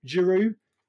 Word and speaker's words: Giroud: And a player that you've signed, but you struggled to pronounce his name right, Giroud. You don't have to pronounce the D, Sean.